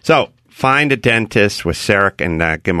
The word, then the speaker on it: CEREC: CEREC. And it rhymes with Eric.